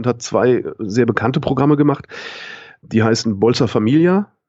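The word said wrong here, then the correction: Bolsa Família: nee, Bausa, Bausa Familia, so spricht man es aus.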